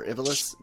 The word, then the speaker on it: Ivalice: and that's the world of Ivalice, or Ivalice, depending on who wants to pronounce it.